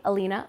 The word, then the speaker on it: Alina: Well, you guys can find me on Instagram at Hey Alina Alive. And my name is spelled A-L-I-N-A.